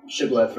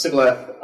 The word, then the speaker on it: shibboleth: So we have the example of language as a form of gatekeeping with shibboleth versus sibboleth pronunciation. Where it's like, if you can pronounce this one consonant that is a difficult consonant, then you probably were raised speaking this language.